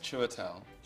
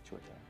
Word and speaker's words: Chiwetel: My name is pronounced Chiwetel. – Chiwetel.